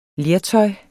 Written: lertøj

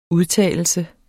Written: udtalelse

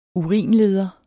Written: URINLEDER

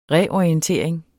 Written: reorientering

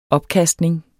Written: opkastning

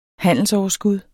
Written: handelsoverskud

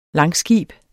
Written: langskib

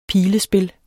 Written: pilespil

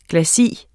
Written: Glacis

